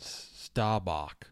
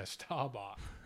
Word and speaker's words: Staubach: Or Staubach, how do you say it? – Staubach. – Yeah, Staubach.